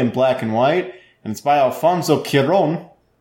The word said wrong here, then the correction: Cuarón: That's not how you say his name. – Cuaron, I think. – Cuaron.